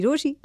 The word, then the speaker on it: Rochie: मेरा नाम है मिर्ची रोशी, नाम की स्पेलिंग है ROCHIE, और कहीं मत जाना जाना, क्योंकि वापस तो यही है आना.